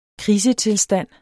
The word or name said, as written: krisetilstand